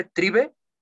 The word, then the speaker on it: Tribe: Trip 12 o 12. ¿Cómo? Se escribe tribe. Tribe, tribe. Tribe. Tribe, tribe. Tribe 12, que es como la tribu Sí, la tribu 12.